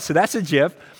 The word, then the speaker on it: GIF: So GIF, G-I-F, that's by the way how you pronounce it, stands for graphic interface format.